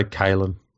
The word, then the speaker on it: Kalyn: Do I say his name wrong? Because I call him Callan. – I'd say Kalen. – Callan Ponga. But his name is K-A-L-Y-N. – Yeah, Kalen.